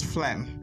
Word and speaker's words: phlegm: So this word has a silent letter, which is the letter G, so it's pronounced as phlegm.